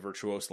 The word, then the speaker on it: Virtuos: Development service provider Virtuous. No. That's not Virtuous. – I kind of pronounce it Virtuous. I guess you could say it's Virtuos. – Yeah. We're going to go with Virtuos.